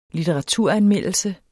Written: litteraturanmeldelse

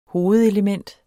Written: hovedelement

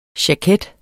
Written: jaket

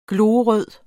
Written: glorød

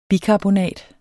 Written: bikarbonat

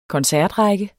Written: koncertrække

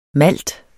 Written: malt

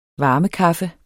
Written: varmekaffe

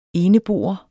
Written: eneboer

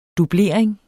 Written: dublering

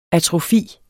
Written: atrofi

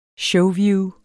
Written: showview

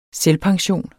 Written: selvpension

